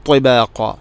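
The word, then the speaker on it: ത്വിബാഖ (طباقا): ത്വിബാഖ ത്വാഇൻ്റെ ഉച്ചാരണം, തൊണ്ടയുടെ ത്വാ ഉച്ചരിക്കുമ്പോൾ നാവിൻ്റെ മധ്യഭാഗം അണ്ണാക്കിലോട്ട് വരണം. അതോടൊപ്പം നാവിൻ്റെ അറ്റം മേലെ മുൻപല്ലുകളുടെ മുരട് അഥവാ പല്ല് ഇങ്ങനെ കുത്തി നിൽക്കുന്ന തൊട്ട് ബാക്കിൽ ആണ് അതിൻ്റെ മഹ്റജ്.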